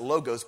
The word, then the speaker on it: logos: Logos. Some people pronounce it logos, but it's actually logos. The O is ah.